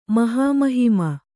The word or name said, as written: ಮಹಾಮಹಿಮೆ